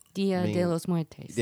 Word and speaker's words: Dia de los Muertos: I'm going to probably destroy this pronunciation. Mexico's Dia de los Muertos. – Dia de los Muertos. – Yeah, that's it.